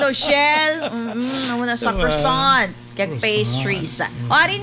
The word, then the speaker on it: croissant: Croissant.